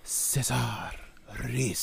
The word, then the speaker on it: Cesar Ruiz: And by the way, I think you're pronouncing his name incorrectly. You two should be ashamed. It should be Cesar Ruiz. So I think it's the way that you got to actually put the essence in the whisper.